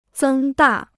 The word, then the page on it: 增大: zēng dà